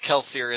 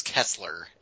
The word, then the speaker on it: Kelsier: Or unless you pronounce Kelsier as Kessler, and then we're going to...